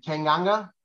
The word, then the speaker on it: Tanganga: Tanganga? – Yes.